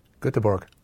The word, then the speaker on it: Göteborg: Richie, ask him what way Malmo's great rivals IFK Gothenburg is pronounced. – Göteborg. – I've never heard you say that. Ever. Ever. – That's how it's pronounced.